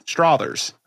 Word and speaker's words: Struthers: I say it more like brothers. Is how you tell me to say it, like Struthers. – No, it rhymes with bothers. – Bothers. Yeah, Struthers.